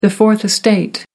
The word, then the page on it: fourth estate: American English